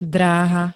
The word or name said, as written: dráha